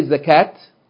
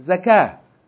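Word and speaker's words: zakah (زكاة): But if you just say zakah, you don't say zakat. That's not correct. You have to pay the zakat. No, you have to pay the zakah. Zakah.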